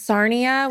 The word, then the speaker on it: Sarnia: It's much worse than Siren. It's Sarnia, which sounds like Narnia with more sandwiches.